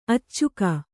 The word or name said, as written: ಅಚ್ಚುಗ